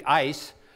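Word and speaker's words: ice (εἰς): I think one of the words for "in" is pronounced, actually in Greek, "ice."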